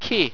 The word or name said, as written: ki